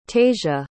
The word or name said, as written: tasia